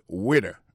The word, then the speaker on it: waiter: Alors moi, je prononce le waiter. Waiter, ça veut dire accent tombé sous le premier syllabe là. Waiter. W-A-I-T-E-R.